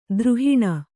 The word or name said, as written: ದ್ರುಹಿಣ